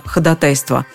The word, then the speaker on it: ходатайство: Ходатайство. Таково правильное произношение этого слова.